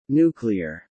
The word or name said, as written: nuclear